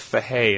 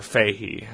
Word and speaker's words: Fahey: What was the Jeff Fahey in that last season of Justified? Or Fahey. It depends on where you're from, how you pronounce that name.